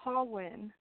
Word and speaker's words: Ha-Nguyen: um, Hawin. – Hawin. – Yes. – So uh like when like I'm winning.